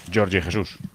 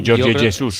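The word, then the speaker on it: Jorge Jesus: Eso es, y que será Jorge Jesús, que se dice así, ¿no, Bruno? O Jorge Jesús. – Sí, sí, o hacer las J's en español.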